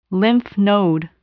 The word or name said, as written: lymph node